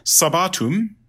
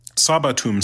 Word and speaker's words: sabbatum: Sabbatum, sabbati, Sabbatum sabbati, sorry, I put the emphasis on the wrong syllable, is Sabbath, comes from the Hebrew Shabbat, the Sabbath.